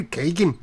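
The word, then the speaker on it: Keoghan: Barry Coogan. How do you say that? Keegan? – Yeah, I gotta say with like an Irish accent. I don't know how to do one. But Barry Keegan? – Yeah, kind of like there that.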